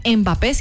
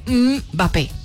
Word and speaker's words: Mbappé: Mbappé, a ver, no se dice Mbappé, sino que se dice Mbappé.